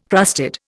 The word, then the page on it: crusted: Indian